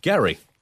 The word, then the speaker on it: Gerry: Well, I think it's pronounced Gary. It, it was Gary, right. but because people are thick, right. it changed.